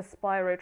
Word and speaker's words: Spyro: Because a game, I mean, this was fixed later down the line, but a collection of games like the Spyro? Yes, that's how you pronounce it.